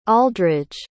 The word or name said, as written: aldridge